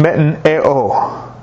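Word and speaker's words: metanoeo (μετανοέω): The word repentance in the Greek language is pronounced metanoeo. Metanoeo. That's how you say it.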